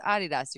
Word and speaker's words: Adidas: No, it's pronounced Adidas. You're right.